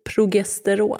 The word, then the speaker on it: Progesteron: Uttalar jag det rätt nu? – Progesteron.